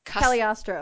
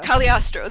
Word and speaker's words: Cagliostro: And a little later in the article, in that discussion of the Castle of Cagliostro, I can't pronounce it. – Cagliostro. – Cagliostro, thank you.